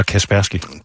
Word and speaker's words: Kaspersky: Jeg skal lige, når jeg har fat i dig, hvordan udtaler man det her? Kaspersky eller Kaspersky? – Det er svært for rigtig mange, men den rigtige måde at sige det på, det er Kaspersky Labs.